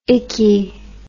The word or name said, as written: ikky